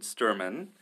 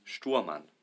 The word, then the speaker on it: Stuhrmann: Well, like you said, most Americans, they pronounce it Stuhrmann, but the proper pronunciation would be Stuhrmann.